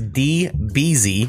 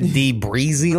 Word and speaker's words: D Beezy: Uh, the artist, I believe I mispronounced the name last time, and I'm not this time, but I mm-hmm. believe it is D Beezy. D Beezy, baby. I want to say I said D Breezy last time, and he was like, no.